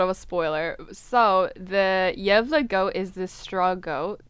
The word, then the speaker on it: Gävle: It's called the Gävle goat. It's Swedish. So phonetically, it's just spelled G-A-V-L-E, but I looked up the pronunciation. It's Gävle.